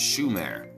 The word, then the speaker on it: Sumer: Um, Sumer is actually pronounced Shumer, and Shumer translated means the land of the watchers.